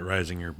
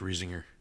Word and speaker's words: Riesinger: As you told me off mic, that's very German, and I was pronouncing it Reisinger, but he's German. That's Riesinger. Makes sense.